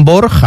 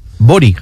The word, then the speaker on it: Borg: Bueno, pues toda la vida diciendo Bjorn Borg, a mí que me encantaba como jugador de tenis, que realmente es como se escribe en español, y era Bori, ¿no? Borig.